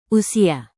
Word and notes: usia: Indonesian

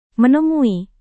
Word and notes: menemui: Indonesian

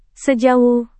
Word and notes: sejauh: Indonesian

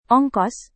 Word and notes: ongkos: Indonesian

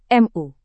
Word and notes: mu: Indonesian

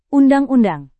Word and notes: undang-undang: Indonesian

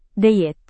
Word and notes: diet: Indonesian

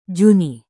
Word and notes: juni: Indonesian

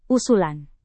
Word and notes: usulan: Indonesian

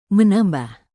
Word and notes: menambah: Indonesian

